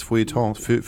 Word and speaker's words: Feuilleton: Aber ihr seid so Lieblinge des, wie heißt das, Feuilletons? – Ja, genau. – Wie spricht man das ja, richtig Feuilleton, aus? Hast du Feuilleton. Gesagt, ja.